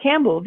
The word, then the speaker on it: Campbell: Campbell, wie die Suppe.